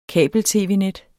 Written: kabel-tv-net

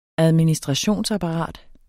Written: administrationsapparat

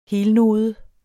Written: helnode

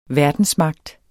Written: verdensmagt

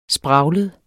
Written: spraglet

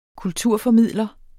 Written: kulturformidler

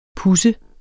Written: puds

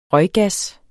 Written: røggas

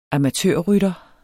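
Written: AMATØRRYTTERE

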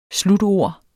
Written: slutord

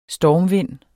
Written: stormvind

